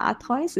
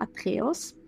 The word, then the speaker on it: Atreus: Korrekte Aussprache, bitte mich nicht anprangern. Es gibt vielleicht verschiedene Aussprachen. Ich sage immer Atreus, es gibt aber auch Atreus.